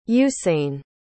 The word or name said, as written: usain